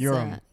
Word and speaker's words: Uram: Doug, say your last name because Uram.